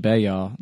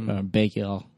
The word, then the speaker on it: Bexar: So I sent him this short script called Bear Territory, and it's pronounced like the French Baya, like Baya, whatever, B-E-X-A-R for Bear County, Texas.